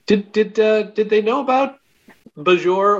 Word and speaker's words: Bajor: We have yet a new third pronunciation of Bajor. – Oh, yes. – Yeah, not Bajor or Bajor, but Bajor. – Bajor. – It's about the emphasis.